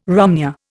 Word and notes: romneya: Indian

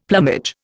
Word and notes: plumage: Indian

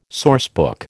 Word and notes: sourcebook: American English

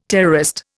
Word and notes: terrorist: Indian

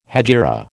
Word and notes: hejira: American English

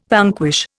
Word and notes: vanquish: Indian

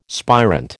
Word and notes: spirant: American English

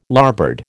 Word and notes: larboard: American English

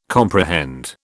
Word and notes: comprehend: British English

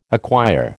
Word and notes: acquire: American English

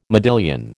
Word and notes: modillion: American English

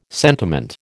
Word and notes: sentiment: American English

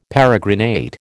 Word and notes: peregrinate: American English

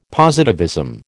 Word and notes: positivism: American English

